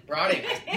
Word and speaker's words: Brateng: Brateng.